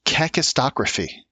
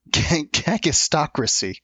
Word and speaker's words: cacistocracy: It's cacistocracy. Excuse me. I didn't say that right. It's cacistocracy. I messed it up. Cacistocracy.